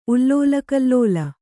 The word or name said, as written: ಉಲ್ಲೋಲಕಲ್ಲೋಲ